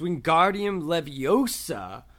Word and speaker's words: Wingardium Leviosa: And Hermione says, you're saying it wrong. It's Wingardium Leviosa. Make the gar nice and long.